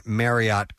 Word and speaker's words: Marriott: And the Marriott team says that if you're pronouncing Marriott correctly, it should actually rhyme with chariot.